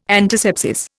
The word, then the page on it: antisepsis: Indian